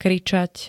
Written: kričať

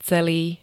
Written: celý

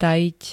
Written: tajiť

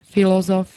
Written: filozof

